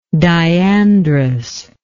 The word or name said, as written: diandrous